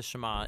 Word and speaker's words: Shema: Now, the first word of the Shema is hear or listen, which in Hebrew is pronounced Shema.